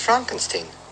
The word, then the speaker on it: Frankenstein: No, it's pronounced Frankenstein. – Do you also say Frederick? – No, Frederick. – Well, why isn't it Frederick Frankenstein? – It isn't, it's Frederick Frankenstein.